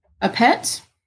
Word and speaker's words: Appert: This sum was awarded to Nicholas Appert. – Sure. – It's A-P-P-E-R-T. – Appert. – Southern Appert. French don't pronounce all the word letters.